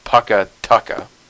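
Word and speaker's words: Pukka Tukka: I believe it is properly pronounced Pukatooka, and it means good food, I believe. But we say Pukatooka, and that's what she calls me, and that's what I've used.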